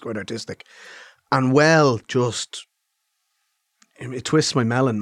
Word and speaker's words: bhuel: Well is something that's existed in the Irish language for a long time and is spelt B-H-U-E-L. And it drives... – Well.